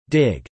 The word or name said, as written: dig